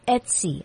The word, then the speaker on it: Etsy: And so what they want you to do is to look at a website called Etsy. I'll spell it for you. It's E-T-S-Y, Etsy. It's like the girl's name Betsy, but without the B.